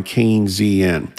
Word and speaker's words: Keynesian: Okay, so that's called Keynesian economics, pronounced like candy cane, letter Z I N, Keynesian.